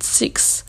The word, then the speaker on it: Sikhs: voilà, et euh, on a fait un don à cet assaut-là, qui s'appelle United Six. Donc United et Six, ça s'écrit S-I-K-H-S.